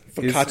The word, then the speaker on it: focaccia: And then focaccia. Focaccia. Is how it's said.